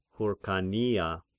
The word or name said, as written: Hyrkania